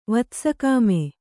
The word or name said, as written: ವತ್ಸಕಾಮೆ